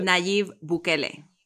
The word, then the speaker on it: Nayib Bukele: Nayib Bukele.